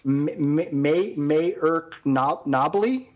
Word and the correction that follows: Mark Noble: Is that how you pronounce that? – Mark Noble, the new PK taker for West Ham? – Mayerk?